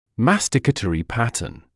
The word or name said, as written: masticatory pattern